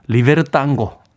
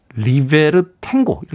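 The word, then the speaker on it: Libertango: 그레이스 존스의 음악 신청했는데 이 곡은 아마 아스토로 피아졸라라고 하는 그 유명한 탱고 아티스트의 리베르 땅고. 네, 우리에게 이제 리버 리베르 탱고 이렇게 발음하죠. 네, 아르헨티나 사람들은 땅고라고 발음한다고 합니다.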